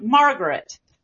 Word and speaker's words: Margaret: But Southerners often put more stress on the first syllable. So when he says Margaret, it's a little bit more like Margaret, where there's more stress on that first syllable, so that makes that word stand out even more.